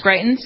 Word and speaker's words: Greitens: By the way, speaking of that, the governor of Missouri, Eric, is it Greitens? Is that how he pronounces this guy's name? I think it is. Greitens? – Yeah, Greitens, yes.